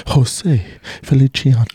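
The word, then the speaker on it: José Feliciano: Or is he fucking Italian? Jose Feliciano. – I think you said it right.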